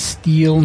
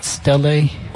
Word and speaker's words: stele: Is that how you say it? Steel, not stele? – Jeez, I always thought it was steel. But you see, this is that problem of words you read but never hear other people pronounce.